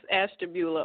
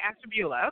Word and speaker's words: Ashtabula: She's born outside of Cleveland, Ohio, in the city of, help me, sweetie, how do we say this? – It's Ashtabula, Ohio. – I was going to say Ashta. Oh, I guess I was going to say the right thing. Okay, Ashtabula.